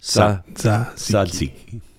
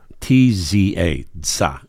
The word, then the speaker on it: tzatziki: You're pretty close. It's tzatziki. – Tzatziki. – Sad? – No. – Za. – T-Z-A. Za.